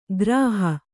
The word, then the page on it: ಗ್ರಾಹ: Kannada